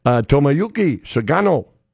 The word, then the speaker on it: Tomoyuki Sugano: Tomoyuki Sugano. Nailed it. – Nice. – Taking on Connor. – Say it slow for Doug. – Tomoyuki Sugano. – Can you spell it? – T-O-M-O. Can you use it in a sentence? T-O-M-O-Y-U-K-I.